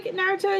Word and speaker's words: Naruto: How do you say Naruto again? – Ah, you did it!